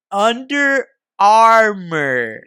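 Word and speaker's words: Under Armour: Like, what? Undermer. And I was just like, huh? And he's like, under. Armor. Yeah, yeah. he was all like, oh yeah, that's how you say it.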